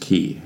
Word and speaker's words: quay: It's pronounced key, isn't it? I've been pronouncing it that way my entire life. – Yeah, it's supposed to be, but it's spelt quay. Nothing. I don't know. It's one of those words that you read and then you pronounce, and then someone's like, that's not how you pronounce it. And you're like, well, that's how it's spelt.